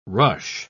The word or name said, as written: rush